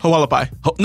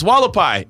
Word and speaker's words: Hualapai: Hualapai. – No, it's not. It's Hualapai. If the H is silent.